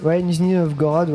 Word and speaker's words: Nizhny Novgorod: Voilà, on n'en passe pas assez, qui nous vient de Nizhny Novgorod. Je sais pas si je le prononce bien, tu auras la meilleure prononciation, j'imagine. Oui, Ouais, Nizhny Novgorod, oui, effectivement.